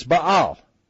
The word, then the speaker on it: Baal: And his real name, believe it or not, was Ish, and I guess in English you pronounce it, and help me, Soapy, is it Baal? Ishbaal? It's Baal.